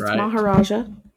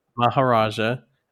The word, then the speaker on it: Maharaja: It's Maharaja. – Maharaja.